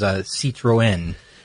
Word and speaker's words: Citroën: I'll try my best to do it the proper way, which is Citroën.